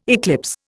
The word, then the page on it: eclipse: Indian